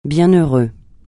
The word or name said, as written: bienheureux